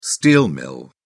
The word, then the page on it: steel mill: British English